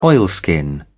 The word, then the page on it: oilskin: British English